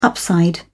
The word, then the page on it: upside: British English